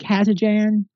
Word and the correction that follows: Kazanjian: I'm probably mispronouncing that. – Kazanjian. – Kazanjian.